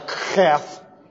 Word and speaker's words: heth: I have to pronounce a heth like a kheth. Starts way down deep with the bouncing of the epiglottis, and then you find a little air through, and you get sort of a guttural punch with it.